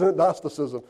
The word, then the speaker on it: Gnosticism: Are the Gnostics, and it's spelled G-N-O, Gnostic, as I want to say, but it's Gnosticism.